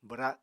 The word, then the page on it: bwra: apatani